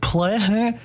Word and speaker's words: Plaehn: I believe it's pronounced Plain. I believe it's pronounced Plain. It's spelled Plaehn. And that's an album I found here at CFNC checking out the library.